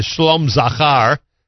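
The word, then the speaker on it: Shalom Zachar: They have a brand new baby boy as of Wednesday, the Shlom Zachar, pronounced properly.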